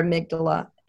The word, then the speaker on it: amygdala: So how we process information is through our amygdala. And I'm probably not pronouncing it right, but it actually exists. I could spell it better than I can say. – That is how you pronounce it.